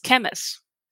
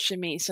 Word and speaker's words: chemise: And the word C-H-E-M-I-S comes up a lot, and I read it as chemist. And so the first time I said chemise, it out loud, right? somebody says that's pronounced, it's French, it's chemise.